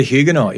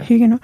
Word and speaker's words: Huguenot: This is the exciting tale of an unsung hero of the Huguenot. Did I say that right? Yeah, the Huguenot, yeah. – Huguenot. The T is silent.